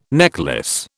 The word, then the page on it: necklace: British English